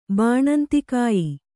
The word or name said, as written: ಬಾಣಂತಿಕಾಯಿ